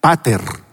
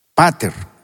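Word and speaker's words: pater (πατήρ): Por ejemplo, en el griego, la palabra padre se pronuncia pater, pater, que significa, el significado en el griego de esa palabra pater es nutridor, el que nutre, protector, que protege, y sustentador.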